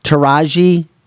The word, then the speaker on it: Taraji: Taraji.